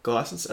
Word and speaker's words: GlySens: Um, correct me if my pronunciation's not correct. Is it the GlySens? Yep. GlySens.